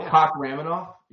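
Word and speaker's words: Kakhramonov: What do you mean you can't pronounce his name, though? This is Kokraminov. – Oh, Kokraminov? Yeah. – Yeah. – That sounds right.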